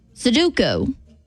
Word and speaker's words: Sudoku: Sudoku. Sudoku. Sudoku. I did say it right the first time. – And number three. Huh? – I don't think I said it right the first time.